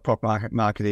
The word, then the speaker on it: ProcMarket: So listen, why don't we start by a little bit of your, telling us a bit of your journey so far, what ProcMarket is, and how long you've had this venture. – So ProcMarket